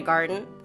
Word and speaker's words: Garten: But I'm going to dig, because I want to know what it feels like to hug Ina Garten. And I also want to know, is that how you pronounce her name? – Yes, that is okay. – Correct.